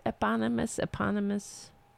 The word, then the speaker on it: eponymous: Trump Magazine, Trump launched his, how do you pronounce that word? Eponymous? Yeah, I said it right.